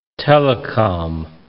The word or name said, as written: telecom